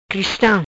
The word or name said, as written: Cristam